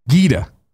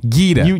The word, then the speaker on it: Gita: White Bishop says, I believe I've been saying Gita Jackson from Polygon's name is pronounced with a hard G as in Gary, not a soft G as in GIF.